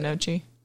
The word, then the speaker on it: gnocchi: Gnocchi. – It has a G in there.